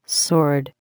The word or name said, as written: sword